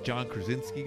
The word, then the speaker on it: John Krasinski: This movie is with that John Krasinski guy.